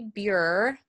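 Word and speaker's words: Burer: So it's Lindsay Buer, which is spelled B as in boy, U-R-E-R, Equine Nutrition Consultant.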